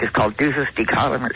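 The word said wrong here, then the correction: duces tecum: or something. I used a regular subpoena. – Deuces Tecum. – Yes, that's how you pronounce it.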